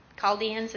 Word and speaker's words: Chaldeans: I don't know if I was the only one, but when I was reading 6:22, I just found the Chaldeans, is that how you say it? – Yes.